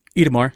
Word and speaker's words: Itamar: Is that how you say his name? Itamar? – Itamar, yeah.